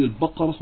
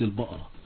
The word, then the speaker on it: البقرة: نعم، أولا نقول للسائلة الطيبة، عندما نقول سورة البقرة نقول البقرة وليست البقرة، لأن هذه لفظة قرآنية تنطق كما جاءت في القرآن فنقول سورة البقرة.